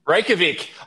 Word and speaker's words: Reykjavik: Reykjavik.